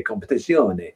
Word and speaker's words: Competizione: Because obviously you mainly do GT Sport, but I found that when I went to a set of Corsa competition on PC, I've pronounced that all wrong. I know people come at me saying it's going to be competizione, but I actually, it helped me improve going back to GT Sport.